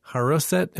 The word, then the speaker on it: haroset: All right, Rabbi Josh, I really appreciate you coming on the show today and sharing the recipe for haroset. Am I saying that correctly? – Very good. Well done. Yeah, you got it.